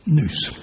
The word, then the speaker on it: nous: Now, that is the Greek word, just as it is, but it's pronounced and spelled nous. Nous is a sailor. It's pronounced nous.